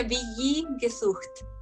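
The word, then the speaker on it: WG-Gesucht: Ah, se pronuncia Billy Gesucht.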